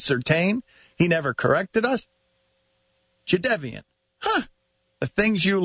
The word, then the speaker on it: Jadeveon: ESPN's Adam Schefter tweets that the correct pronunciation for Jadeveon Clowney's first name is Jadeveon. Second syllable is pronounced like the first syllable in Devin.